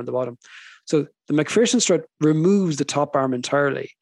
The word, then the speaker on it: MacPherson: Is that the right way to pronounce it? – MacPherson strut. So I mentioned already that the wheel upright is connected at the top and at the bottom by the control arms.